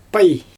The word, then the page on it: paj: Xyzyl